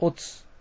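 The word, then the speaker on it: 乙: 音読みで読みますと、こう、おつ、へい、てい、ぼ、き、こう、しん、じん、き、と読みます。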